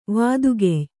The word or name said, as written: ವತ್ತುಗಾಯಿ